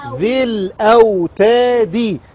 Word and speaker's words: ذي الأوتاد: نحذف الساكن الاول، نحذف الساكن الاول في النطق، وبعدين نخرج من الذال المكسورة على اللام مباشرة. نقول ايه؟ ذل، ذل او تادي، ذل او تادي.